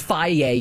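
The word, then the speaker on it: Fage: When you learn it's pronounced Faye, you're like, wow, oh.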